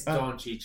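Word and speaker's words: Dončić: It's Doncic. It's not that hard. Doncic. That's all I'm gonna say.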